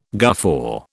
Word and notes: guffaw: British English

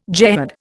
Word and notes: jehad: Indian

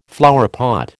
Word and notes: flowerpot: American English

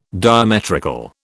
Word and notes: diametrical: British English

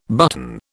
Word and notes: button: British English

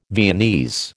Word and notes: Viennese: American English

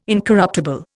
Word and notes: incorruptible: Indian

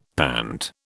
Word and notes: pant: British English

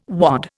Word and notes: wad: Indian

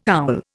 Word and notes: owl: Indian

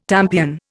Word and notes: tampion: Indian

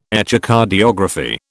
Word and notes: Echocardiography: British English

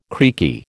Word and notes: creaky: American English

